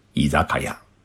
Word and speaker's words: 居酒屋: 居酒屋三个字呢，是汉字。但是在日本当中啊，念作是伊扎卡亚，它的意思是什么呢？